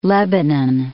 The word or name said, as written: Lebanon